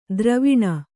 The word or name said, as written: ದ್ರುವಣಿ